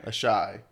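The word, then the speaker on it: Asshai: The only way I see a cure happening is in Asai, or is that how you pronounce it? – Asai.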